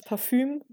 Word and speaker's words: Parfum: Und da haben wir, oh Gott, dieses Wort ist auch ein Wort, was man nur falsch aussprechen kann, das Parfum gelesen. – Ah, ja, ja. – Das Parfüm. Das Parfüm, ne? Ja.